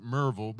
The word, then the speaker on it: Maryville: It, it's spelled Maryville, but we pronounce it Merville because we're from the southeast.